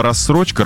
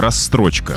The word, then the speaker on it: рассрочка: А Коля нам написал еще, он говорит, что его всегда радовала фразу, которую мама произносила вместо слова «рассрочка» — «расстрочка».